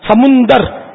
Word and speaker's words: سمندر: تو ابھی ایک سمندر ہم بولتے ہیں, تو اصل میں یہ سمندر جس کو ہم بولتے ہیں نا, یہ پانی کا ذخیرہ, یہ سمندر میم پر پیش ہے, صحیح تلفظ سمندر.